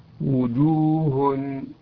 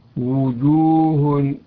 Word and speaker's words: وجوه: وأن يعطيها حقها ومستحقها، فإذا جاءت مضمومة فأحكم الضم نحو وجوه وجوه. البعض ينطق وي وي وليست وو، وو وليست وي.